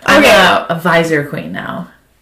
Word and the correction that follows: Pfizer: Did I say that right? – No, it's Pfizer.